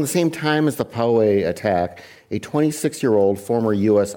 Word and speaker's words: Poway: Am I pronouncing Poway. – That correct? Poway, thank you.